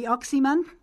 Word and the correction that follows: Oxman: The Oxman, yeah, no, E-I, so it's O-X-M-A-N. Oxman.